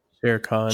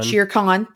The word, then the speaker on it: Shere Khan: Shere Khan. – Shere Khan.